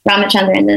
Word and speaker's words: Ramachandran: Ramachandran is not that hard, nor is Janani.